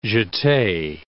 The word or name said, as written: jete